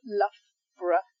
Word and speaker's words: Loughborough: So Scarborough, Loughborough. It's not borough, it's borough. So you don't pronounce it as two syllables, it's just one syllable here. So yeah, Loughborough and Scarborough.